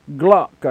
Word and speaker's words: Glock: I bought a 9mm Glock, I think it's called. I remember that because it rhymes with clock.